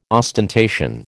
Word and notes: ostentation: American English

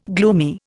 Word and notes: gloomy: Indian

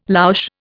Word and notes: louche: Indian

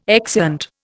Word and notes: exeunt: Indian

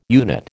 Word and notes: unit: American English

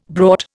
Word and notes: brought: Indian

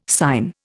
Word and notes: sine: Indian

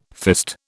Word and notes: fist: British English

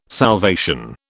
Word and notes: salvation: British English

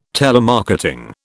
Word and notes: telemarketing: British English

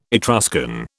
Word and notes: Etruscan: British English

